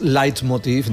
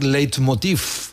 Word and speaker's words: leitmotiv: Es leitmotiv en alemán, pero ya la Real Academia nos permite decir leitmotiv. Tal y como se escribe, sin multa retrógrada ni nada.